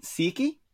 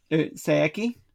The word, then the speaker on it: Saeki: Yuto Tsukuda, and illustrated by Shun Saeki? Saeki, I should say.